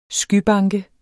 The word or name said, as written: skybanke